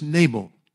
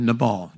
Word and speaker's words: Nabal: The name of the man was Nabal, or Nabal. You pronounce it whichever way you want.